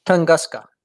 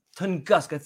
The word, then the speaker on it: Tunguska: And the one in Russia, the Tuscanga, or I forgot what that was called again. – Tunguska. – Tunguska.